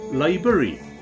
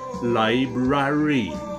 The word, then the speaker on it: library: What comes natural to me to say is library. And I have to make an effort to remember that the word has two letter R's in it. And it's supposed to be pronounced library.